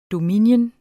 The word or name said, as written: dominion